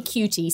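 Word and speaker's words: QT: who created a fake pop star named Cutie and has made this incredible song called Hey Cutie, spelled like letter Q, letter T.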